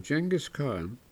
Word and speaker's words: Genghis Khan: About 900 years ago, Genghis Khan, pronounced internationally with a soft G,